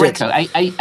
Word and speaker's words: Aggreko: Okay, that's how do you pronounce it? Aggreko. – Aggreko. That's Aggreko. A-G-K.